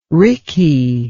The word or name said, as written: ricky